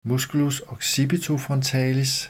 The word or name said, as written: m. occipitofrontalis